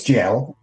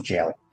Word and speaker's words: gaol: Is it, how do you pronounce that? G-A-O-L. – It's jail. – Okay. – And I just say the Americanized version, jail.